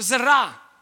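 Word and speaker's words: zerah (זרע): It's a word in Hebrew which is somewhat pronounced zerah.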